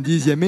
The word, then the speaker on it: dixième: Deux mots dans lesquels la lettre X se prononce Z, sixième, dixième.